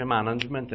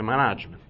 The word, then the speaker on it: management: Ripeto, non so se ve l'ho detto, si dice performance, non si dice performance, come tutti dicono. Come management si dice management e non si dice management. Piccole parole che vanno in qualche modo sapute dire, perché si fa un'altra figura.